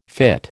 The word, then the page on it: fytte: American English